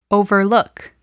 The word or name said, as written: overlook